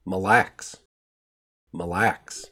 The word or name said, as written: Mille Lacs